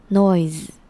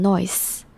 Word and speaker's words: noise: 接下来我们来看 noise 这个字， noise 这个字是指噪音，那后面虽然是 se，但它其实是发成类似 z 的声音， noise， noise，不要念成 noise，不要把那个 s 讲得真的很有那个气音 s 的感觉，要把它发出来然后讲成 z 的发音， noise， noise。